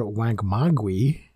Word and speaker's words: Wangmagwi: So, yes, the reason we have you here today is to talk about a particular kaiju movie, Space Monster Wangmagwe. I think I'm pronouncing that correctly. – That's how I pronounce it.